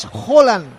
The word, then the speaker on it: Haaland: Me manda un mensaje un amigo noruego, me dice que es Haaland. Lo que pasa es que, claro, no podemos saber todos los idiomas. – Sí, sí, es verdad que se pronuncia así, es verdad.